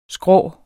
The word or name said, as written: skrå